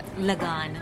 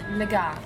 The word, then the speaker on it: Lagaan: So we are talking about how do you pronounce it? – Lagaan. Lagaan. – Yeah, Lagaan. Okay.